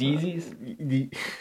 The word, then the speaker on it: Yeezy: His Yeezys? Or, maybe I said that wrong. – You better leave that. Yeezy? Yes. Yes.